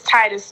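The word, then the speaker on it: Tytus: Yeah, you guys can find me on YouTube, Miss Titus 2.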